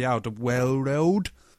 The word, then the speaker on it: Alderweireld: They've signed Toby Alderweireld from Atletico Madrid.